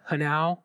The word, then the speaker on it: Hanau: Do you think it's a coincidence that Hanau rhymes with Tau?